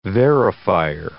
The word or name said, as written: verifier